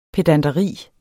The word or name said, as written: pedanteri